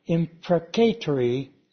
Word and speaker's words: imprecatory: imprecatory is actually the correct way of pronouncing that, prayer.